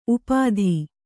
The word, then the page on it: ಉಪಾಧಿ: kannada